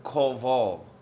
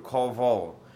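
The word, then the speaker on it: kavah (קוה): The word here for wait is the word kavah in the Hebrew. Kavah. You spell it Q-A-V-A-H, pronounce it kavah.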